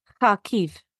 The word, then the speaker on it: Kharkiv: Kharkiv.